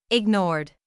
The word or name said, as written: ignored